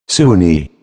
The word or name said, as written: soon